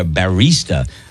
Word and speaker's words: barista: A barrister is an old-timey lawyer. Barista. I did say it incorrectly. Barista.